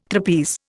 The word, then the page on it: trapeze: Indian